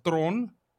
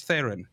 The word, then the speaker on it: Theron: Because her surname is pronounced Thron. It's Charlize Theron. That's the Afrikaans way.